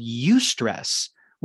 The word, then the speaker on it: eustress: But I want to make a point of mentioning this term that you may or may not be familiar with called eustress, which is spelled E-U-S-T-R-E-S-S. So it's stress with E-U at the beginning.